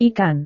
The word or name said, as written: ikan